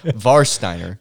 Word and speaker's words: Warsteiner: No, Warsteiner. – Yeah. – W's are pronounced as V's.